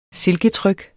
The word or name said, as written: silketryk